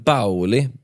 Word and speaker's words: Baoli: Hemska bråket utspelar sig eh, nattklubben Bauli, B-A-O-L-I, Bauli uttalar jag det.